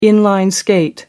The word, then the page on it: in-line skate: British English